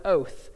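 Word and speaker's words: oath (אות): So, the Hebrew word here for sign is the word oath. That's how it's pronounced, but it's not to be confused with our word oath, as in promise.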